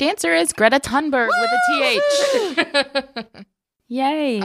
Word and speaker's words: Thunberg: I think it's pronounced Thunberg, but it's with a TH. Thunberg, Greta Thunberg.